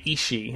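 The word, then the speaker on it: aos sí: That's A-O-S-S-I. Again, never sounds like what it's spelled like. The Ishi.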